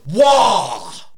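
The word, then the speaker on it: Waaagh: You pronounced that wrong. It's Wah!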